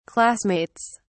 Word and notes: classmates: British English

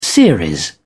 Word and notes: series: British English

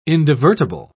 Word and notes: indivertible: British English

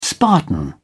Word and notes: spartan: British English

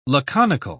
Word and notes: laconical: British English

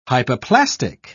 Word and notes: hypoplastic: British English

